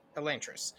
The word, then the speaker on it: Elantris: Elantris. Okay. At least that's how I always pronounced it.